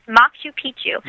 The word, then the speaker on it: Machu Picchu: So the correct way to say it is Machu Picchu? – Yes, Machu Picchu. – With a and hard C in there.